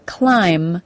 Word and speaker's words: climb: The final sound in the word climb is the M sound, not a B sound. Climb, not climb. This rule is simple. If a word ends in the letters MB, the B is silent.